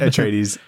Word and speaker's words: Atreides: Is that how you say it? – Yeah, I'm sure. – Atreides? In my head? – Atreides.